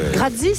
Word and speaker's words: grazie: Grazie, ça se prononce comme ça, grazie.